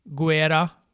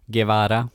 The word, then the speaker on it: Guevara: Santana och Ortiz, han pratar om dem, han pratar om um, Sammy Guerra. – Guevara.